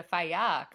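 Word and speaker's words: Falla: including the Fala coming up, the Faya coming up this weekend. Don't pronounce the double L.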